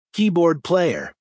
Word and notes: keyboard player: American English

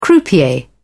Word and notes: croupier: British English

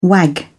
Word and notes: wag: British English

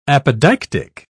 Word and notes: apodeictic: British English

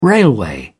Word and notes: railway: British English

